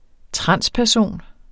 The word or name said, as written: transperson